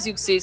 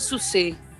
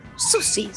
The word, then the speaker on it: Siouxsie: no se dice Siouxsie, se dijo Suse. Y yo, ¿qué decís? Se dice Suse. Y tiene razón. – ¿Se dice Suse? – Se dice Susi, se dice, no se dice Susi, pero bueno, en fin, acá en Argentina le decimos Susi, yo digo Susi.